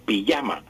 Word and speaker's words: pijama: Aunque lo escribamos con J, pues pronunciamos con Y, pijama, el pijama, o la pijama.